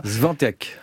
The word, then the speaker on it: Świątek: L'américaine Sofia Kenin, sixième joueuse mondiale, affronte la polonaise Iga Świątek. J'espère que ça se prononce comme ça. – Świątek. – Bravo.